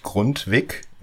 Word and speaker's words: Grundtvig: Der schreibt sich Grundtvig und ausgesprochen wird er, wenn ich es hoffentlich richtig mache, Grundtvi.